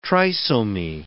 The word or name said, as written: trisomy